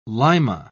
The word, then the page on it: lima: British English